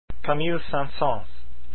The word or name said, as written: saint-saens